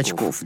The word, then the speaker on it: Бочков: Ну, я бы сказал, что я Бочков. – Бочков, да. Вот это вот, это ошибка, которую я допустил, прошу прощения, всегда уточняю ударение, а тут вот не попал.